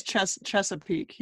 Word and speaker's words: Chesapeake: Is that how you say it? Chesapeake? – I think it's Chesapeake, yeah.